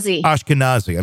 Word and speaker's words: Ashkenazi: Hackers compiling a list of people with, and apologize if I'm saying this incorrectly, Ashkenazi Jewish ancestry. – Ashkenazi. – I'm sorry. Ashkenazi Jewish ancestry seems to have been the specific focus of the attack.